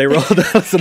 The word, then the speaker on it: Roald: Well, I think most people say Roald Dahl, and I do as well, but yeah, it's Roald, it's a Norwegian name.